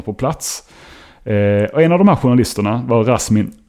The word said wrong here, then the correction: Rasim: Aleyev, med reservation för uttalet, Rasim Aleyev.